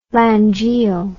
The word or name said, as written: langiel